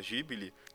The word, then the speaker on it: Ghibli: Pra nível de curiosidade, uma trivia interessante, Ghibli, que na verdade eu descobri que se pronuncia Ghibli,